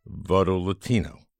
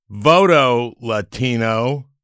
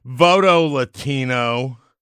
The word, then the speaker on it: Voto Latino: And that's how you pronounce it, Voto Latino. It's nice. It's not Voto Latino, like some xenophobes might do. Voto Latino. It's not a command.